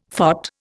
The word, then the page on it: phot: Indian